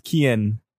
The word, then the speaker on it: Cian: Sweet C-I-A-N. – The sweetest boy. – It's spelled. It's pronounced Kian.